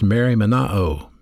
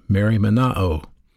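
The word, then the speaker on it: merimnao: It's a verb pronounced merimenao, merimenao.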